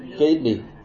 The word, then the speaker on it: Gaitley: Gaitley, is that good pronounce? Gately. Yeah.